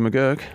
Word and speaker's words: McGurk: Jake Fraser McGurk. – Yeah, it's not McGurk. It's berserk.